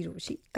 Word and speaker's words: Rochie: मेरा नाम है मिर्ची रोशी, काम है आपसे प्यार करना, नाम की स्पेलिंग है ROCHIE.